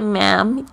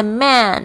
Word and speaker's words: ma'am: 这个 ma'am 就是 madam，不要念成 man，然后嘴巴没有合起来，这个就是说指这个老兄的意思，就把对方讲成男人的意思哦，就不是这样。